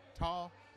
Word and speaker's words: Taupo: And I wanted to ask you, Cam, just about that kind of legacy and what having an event like, oh, sorry, I'm mispronouncing it. It's Taupo.